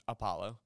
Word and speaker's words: Apollo: But whenever we would be talking to Korean people, their accent, they had trouble pronouncing the ah, so they would pronounce my name Apollo. And so I eventually got it in my head that whenever I was talking to normal military people, just Apollo, but whenever I'm talking to Korean people, I would make sure to pronounce it slowly as Apollo to accommodate.